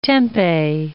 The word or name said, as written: tempeh